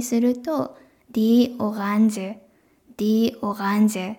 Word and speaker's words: Orange: これフランス語から来てるのでフランス語の発音なんですけど、これがオランジュ、オランジュっていう風に言います。